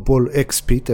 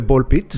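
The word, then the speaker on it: Ball x Pit: או בול אקס פיט, איך שכותבים אותו, אבל הוגים את זה בול פיט. – The X is silent.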